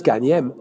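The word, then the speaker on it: gan hyem: And in Geordie, like Danish, in Geordie it's Ganyem. To go home is Ganyem.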